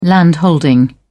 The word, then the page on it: landholding: British English